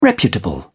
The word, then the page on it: reputable: British English